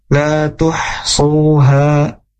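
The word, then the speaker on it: lā tuḥṣūhā: Jadi ketika mengucapkan huruf h maka biasa aja gitu ya dalam pengucapannya. La tuhsuha, suha itu enggak la tuhsuha ha, terlalu takaluf gitu ya, terlalu berlebihan dalam mengucapkan huruf ha seperti itu.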